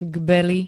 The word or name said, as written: Gbely